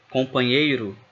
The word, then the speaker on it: companheiro: La prima O di companheiro suona proprio come una O. La Z si legge come una S tra vocali. La NH di companheiro si può leggere come la GN italiana.